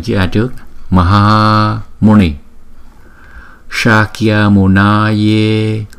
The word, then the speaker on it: Shakyamunaya: Chỗ này chúng ta có bốn chỗ chúng ta cần chú ý nè. Chữ S chúng ta đọc là S S S. Không? Chúng ta đọc là phần palatal thì chúng ta đọc là Shakya Shakya. Không? Chữ A có kéo dài một chút. Shakyamunaya. Chữ N là N N. Và chữ A là chúng ta đọc hai nhịp.